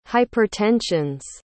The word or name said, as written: hypertensions